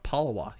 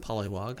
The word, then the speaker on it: Palawa: Palawa. Yes, I pronounced that right. Palawa people. P-A-L-A-W-A.